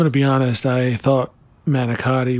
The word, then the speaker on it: manicotti: I kind of wrote my paper on manicotti. – Oh, yeah, you got the pasta entirely wrong, huh? – Mm-hmm. Oh. I'm hearing it now, and I'm a little nervous. – Mm-hmm. It's pronounced manigot.